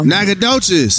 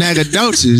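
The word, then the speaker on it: Nacogdoches: To see it spelled out, Nagadoches does not come to mind.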